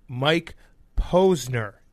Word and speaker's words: Mike Posner: And then we have musician Mike Posner.